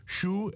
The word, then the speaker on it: sure: Sure, S-U-R-E, ça veut dire certainement. En effet, sure, d'écrit sûr, mais c'est sure, vous prononcez en anglais. Sure, S-U-R-E.